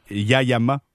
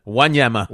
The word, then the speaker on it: Wanyama: Mais une chose est certaine, c'est que la venue de Thierry Henry semble avoir joué pour beaucoup dans l'avenue de Yayama. – Yayama. – comment on le prononce euh, déjà? Wanyama.